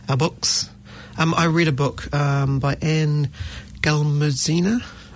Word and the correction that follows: Glamuzina: Glamuzina, I think you're supposed to pronounce it these days.